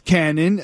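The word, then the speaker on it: Kannon: My name is Cannon, which is spelled with a K, and this is weird, but you live at my old house.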